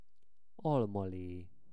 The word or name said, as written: almale